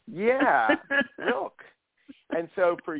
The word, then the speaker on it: Rilke: reminds me, because I was first turned on to Rilke's poetry by a friend of mine who's a painter, and still to this day, it's like, you know, he's a fellow artist.